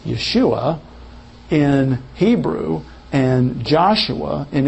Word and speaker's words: Yeshua: Yeshua. Yeshua. If it's pronounced Yeshua, you just simply know that that's where that German influence is in our translations. They, instead of a Y, they put a J there.